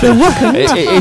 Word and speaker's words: The Weeknd: The Weeknd, I think it's pronounced. – The Weeknd. – It's spelt wrong. It's Weeknd.